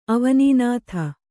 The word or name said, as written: ಅವನೀನಾಥ